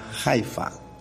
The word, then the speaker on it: Haifa: En Tierra Santa, al norte de Israel, que hoy está en guerra, hay una región, una ciudad que se llama Haifa, con H, Haifa se dice. Ellos le dicen Yafu o Haifu, algo así, pero se escribe Haifa.